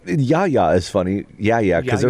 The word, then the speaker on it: Yahya: But when I looked at this a couple hours ago, I looked up the proper way to pronounce it in Arabic. And it's in Yahya. – Yahya.